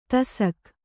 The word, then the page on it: ತಸಕ್: tasak